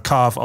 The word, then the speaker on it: Cave: Es gab, in Heidelberg gibt es das Cave, wird ja ähnlich geschrieben, aber bei uns wird es halt Carve ausgesprochen.